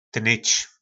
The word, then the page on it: tnyc: po nasimu